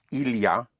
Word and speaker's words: il y a: Il y a, spelled I-L, Y, and A. Il y a, but pronounced il y a.